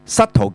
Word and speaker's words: subtle: subtle 記住呢個字喎，個 B 字唔發音喎嚇、啊，就唔好讀，梗係唔係啦？個 B 字唔發音噶嚇、啊、所以佢話 subtle 咧，即係話係好巧妙、好微妙咁解。